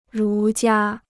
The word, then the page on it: 儒家: rú jiā